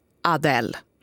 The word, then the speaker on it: Adele: I uttalshänvisningarna vad gäller Adele så står det i det programmet som skickar ut låtarna i Sveriges Radio så står det uttal kolon A-D-E-L-L, Adele.